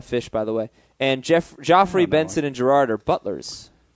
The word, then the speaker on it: Joffrey: How do you feel about Jeffrey, G-E-O, like that? – It's actually Joffrey. Joffrey, Benson, and Gerard. – Is it pronounced Joffrey, really? – Yeah.